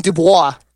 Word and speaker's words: Dubois: And I'll be Daxon Dubois. I'm dead serious. It's spelled D-U-B-O-I-S. A little French right there.